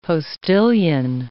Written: postilion

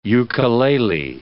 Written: ukelele

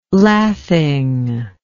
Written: lathing

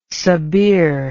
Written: sabir

